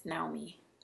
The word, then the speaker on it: Naomi: It's Naomi. That's the Japanese way of pronouncing it.